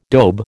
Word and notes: daube: American English

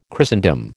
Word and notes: Christendom: American English

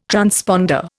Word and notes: transponder: Indian

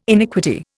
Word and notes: iniquity: Indian

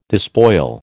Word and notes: despoil: American English

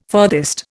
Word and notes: furthest: Indian

